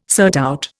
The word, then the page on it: surtout: Indian